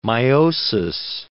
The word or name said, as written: meiosis